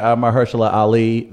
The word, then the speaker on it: Mahershala Ali: Well, because the character played by Mahershala Ali, the family was You did his name very well, by the way.